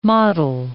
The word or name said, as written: mottle